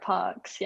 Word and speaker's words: Parkes: Yeah, you pronounced it right, Parks, yeah.